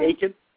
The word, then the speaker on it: Aiken: Am I saying it right, Aiken? – Yes, you're saying it correctly.